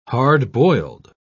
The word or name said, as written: hard-boiled